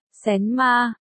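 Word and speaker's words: xén ma (神马): Từ này đọc là, xén ma, đồng âm với từ, xâm me, xén ma, nghĩa là cái gì?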